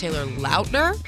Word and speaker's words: Taylor Lautner: Jimmy Allen, Gabby Barrett, Taylor Lautner, which I discovered it's actually pronounced Taylor Lautner.